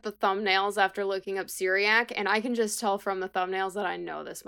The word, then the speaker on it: Cyriak: So, Syriac, and I will get the spelling very quickly here. So, C-Y-R-I-A-K, Syriac, is the best pronunciation that I can assume.